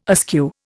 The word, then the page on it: askew: Indian